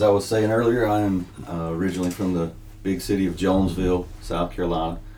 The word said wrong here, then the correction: Jonesville: I completely just said it incorrectly. It's Jonesville. The S is silent.